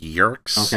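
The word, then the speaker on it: Yeerks: Yurks. Yurks, how do we want to decide they are pronounced? – I thought that was pretty much perfect. Yurks.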